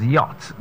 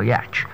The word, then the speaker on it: yacht: So it's, I simply said as yacht, that's why it's pronounced the yacht, not a yatch.